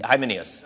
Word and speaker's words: Hymenaeus: Hymenaeus. – For some people have deliberately violated their consciences. As a result, their faith has been shipwrecked. Hymenaeus and Alexander are two examples of this.